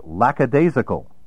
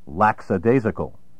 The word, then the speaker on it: lackadaisical: Common synonyms of fainéant include do-nothing, shiftless, slothful, and lackadaisical, which is often mispronounced lackadaisical. There is no lax in lackadaisical. More challenging synonyms of fainéant include lethargic, indolent, somnolent, torpid, otiose, O-T-I-O-S-E, and also hebetudinous, the adjective corresponding to the noun hebetude, word 20 of level 10.